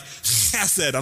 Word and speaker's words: chesed: You've got to get the Middle Eastern like chesed. I'm not going to do that.